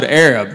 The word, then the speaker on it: Arab: It's spelled A-R-A-B. They don't pronounce it Arab. They pronounce it Arab because the original name was Arad, but they misspelled it on the water tower.